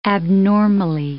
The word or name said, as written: abnormally